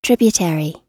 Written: tributary

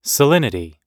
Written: salinity

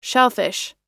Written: shellfish

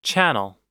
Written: channel

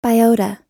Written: biota

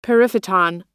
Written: periphyton

